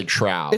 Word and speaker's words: trowel: Uh, is that how you pronounce it? Troll, trowel? – I ha- I'm gonna say trowel.